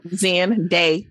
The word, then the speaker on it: Zendaya: No, it's said how it's spelled. Zendaya.